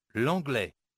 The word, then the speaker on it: l'anglais: L'anglais.